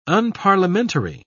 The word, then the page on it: unparliamentary: British English